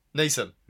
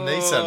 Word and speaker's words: Neeson: Neeson. – Yeah, you've said his name wrong. – Why do I do that every time? Liam Neeson.